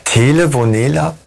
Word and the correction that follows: Telenovela: Telenovela für Arme.